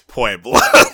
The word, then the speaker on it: Puebla: We have Pribela on June 19th and the 20th. – It's Puebla.